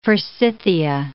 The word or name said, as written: forsythia